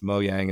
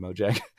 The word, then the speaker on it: Mojang: I think it's Mojang is the proper pronunciation, but I always call them Mojang.